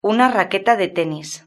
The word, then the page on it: una raqueta de tenis: European Spanish